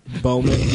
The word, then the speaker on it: Bowman: Bowman, B-O-W-M-A-N, on Twitter and Instagram.